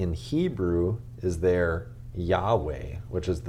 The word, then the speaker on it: Yahweh: So from the beginning throughout the Old Testament, God was called, well, we call him Yahweh. We're pretty sure that that's how you pronounce it in the Hebrew.